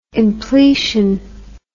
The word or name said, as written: impletion